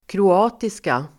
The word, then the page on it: kroatiska: [kro'a:tiska]